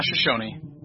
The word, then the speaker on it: Shoshone: For me, I'm looking forward to playing the Shoshone. Is it Shoshone? They need like a pronunciation guide for us. So yeah, I believe it is.